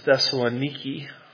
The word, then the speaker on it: Thessaloniki: They pronounce it as Thessaloniki, which is always disappointing, learning you've been mispronouncing a word your whole life.